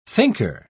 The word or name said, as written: thinker